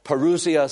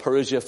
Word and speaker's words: parousia: The word coming here, by the way, is the word parousia or parousia. Some like to pronounce it. We'll pronounce it parousia for talk's sake.